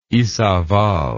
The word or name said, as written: Izabal